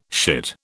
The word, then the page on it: shit: British English